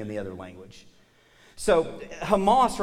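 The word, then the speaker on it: Hamas (חמס): But this word, because of the CH, makes a sound that's actually pronounced Hamas.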